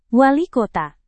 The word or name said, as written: wali kota